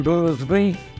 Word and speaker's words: Boothby: Yes, my name is John Boothby. Surname B-O-O-T-H-B-Y.